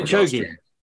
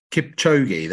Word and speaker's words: Kipchoge: And that, I think his name is Kipochi, and I apologize if I pronounced that wrong. I think I have, or Kipochi, the fastest guy who did it. He broke the world record. Kipchoge. Kipchoge, there we go.